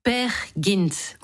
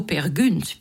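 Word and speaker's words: Peer Gynt: Per Gynt, ou plutôt Per Gynt, puisqu'en norvégien, le Y se prononce U.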